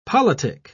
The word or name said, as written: politic